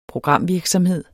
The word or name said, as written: programvirksomhed